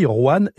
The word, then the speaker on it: Rouane: C'est là où on voit que l'écriture des noms évolue, parce que c'est la forme d'ancienne de Juan qui s'écrivait R-O-I-S-N-E, mais on prononce toujours pareil, Juan, R-O-U-A-N-E.